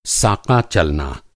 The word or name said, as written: साका